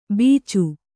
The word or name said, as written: ಬೀಚು